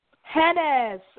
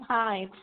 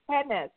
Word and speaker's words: Hennes: Hennes. Okay, I was in Hines. Sorry, it's Hennes. Hennes. – That's okay. – And it's spelled okay. – H-E-N-N-E-S.